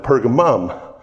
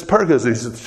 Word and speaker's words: Pergamus: Some places they put, pronounce it Pergamum. But this is Pergamus.